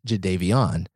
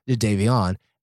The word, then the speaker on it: Jadeveon: And so there's one, we're in a sports center meeting, and one day at a press conference, he goes, "Hey, my name is actually pronounced Jadeveon." – Right. – They have been calling him Jadeveon Clowney, Jadeveon. He's like, "No, it's actually pronounced Jadeveon."